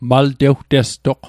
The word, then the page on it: maltewtestoq: mal'tewtestoq; Mi'gmaq